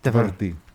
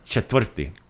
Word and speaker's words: četvrti: Četvrti. – So the accent's on the first syllable. Četvrti. I'm sorry, it's on the second. Četvrti.